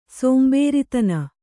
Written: ಸೋಂಬೇರಿತನ

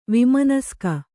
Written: ವಿಮನಸ್ಕ

